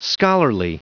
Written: scholarly

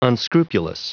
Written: unscrupulous

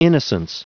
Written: innocence

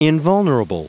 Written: invulnerable

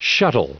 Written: shuttle

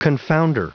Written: confounder

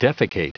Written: defecate

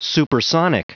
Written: supersonic

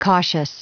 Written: cautious

